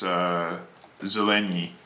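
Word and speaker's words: Zeleny: But Beer does, extends it, and this other guy, Zelenye, is that the correct pronunciation? – I think it's Zelenye, yeah.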